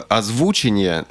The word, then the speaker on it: озвучение: В, словаре Ожегова нету этого слова, а вот а, в каком-то другом, забыл, короче, в каком, но там есть слово «озвучение» э, с ударением на «у».